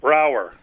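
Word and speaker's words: Braugher: How do you pronounce Andre's last name? – Brower. – Okay, Brower. Andre Brower, genius.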